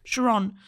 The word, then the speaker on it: Charon: Charon.